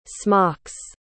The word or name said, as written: smocks